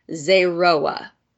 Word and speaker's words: zeroa: So it comes from this Hebrew word pronounced zeroa, and it's spelled Z-E-R-O-A with a little accent thingy. I'm waving my hand in the air to help you understand what I mean with no camera on. Anyway, it's pronounced zeroa.